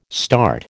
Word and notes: START: American English

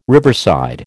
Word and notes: riverside: American English

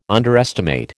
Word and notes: underestimate: American English

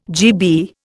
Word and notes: GB: Indian

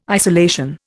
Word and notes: isolation: Indian

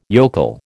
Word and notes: yokel: American English

